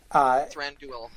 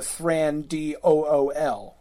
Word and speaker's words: Thranduil: But again, Tolkien has said the UI is pronounced like ruin. So when I pronounce Thranduil, Thranduil. – It's true that it's not like, you know, Thran-D-O-O-L. Like, you shouldn't pronounce it like that.